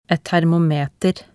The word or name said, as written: et termometer